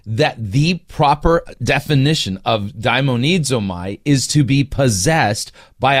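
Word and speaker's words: daimonizomai (δαιμονίζομαι): So his main argument that the the Greek word, by the way, he mispronounced it. It's da- daimonizomai, that it doesn't mean to be possessed by a demon.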